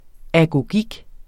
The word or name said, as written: agogik